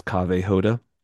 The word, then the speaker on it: Kaveh Hoda: My name is Kaveh Hoda.